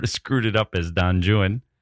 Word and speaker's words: Don Juan: So, yes, it's pronounced Don Juan. We all know the Spanish, including Jonathan Keeble, the narrator. Poor narrator, taking some shots on Audible. Here's the thing. Byron's Don Juan actually is Don Juan. That's just how it is.